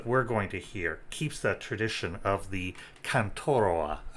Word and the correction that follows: cantaora: I guess is how you, cantaora, I guess is how you pronounce it,